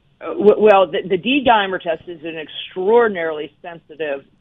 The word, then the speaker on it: D-dimer: My dad did a D-dimer. Am I saying that right? D-dimer test? – Yes.